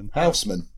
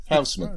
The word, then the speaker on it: Housman: How do you pronounce Housman then? – Housman, as you just did. Housman.